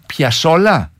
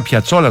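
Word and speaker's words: Piazzolla: Και Άστορ Πιασόλα. – Σωστότατα. – Μισό λεπτό. Το άκουσα να το λέει. Εγώ τον έλεγα Πιατσόλα τόσο καιρό.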